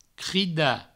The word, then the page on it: cridar: crida